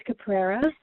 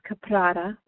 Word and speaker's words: Caprara: My last name can be pronounced Caprera, or you can use the Italian pronunciation, which is Caprara, with those beautiful rolled R's, but here in North America, we tend to just use Caprera.